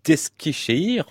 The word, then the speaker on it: Eskişehir: Chef d'orchestre, et notamment depuis près de 30 ans de l'Orchestre symphonique universitaire de Grenoble, également de l'Orchestre symphonique d'Eskişehir en Turquie.